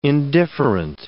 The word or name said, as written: indifferent